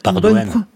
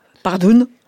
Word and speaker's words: Pardoen: Est-ce que j'ai bien prononcé votre nom qui s'écrit P-R-D-O-E-N ? – Tout à fait. Pardoen. Bonne prononciation. – C'est un mot flamand, je crois ? – Alors oui, c'est un nom flamand. Donc on peut aussi le prononcer à la flamande, Pardoen.